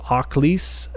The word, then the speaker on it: Akhlys: Um, the band, I believe the pronunciation is called Akhlys, and uh, it's A K H L Y S. At least that's the way it's um, it says that you're supposed to pronounce it on their page on Metal Archives.